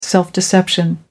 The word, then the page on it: self deception: American English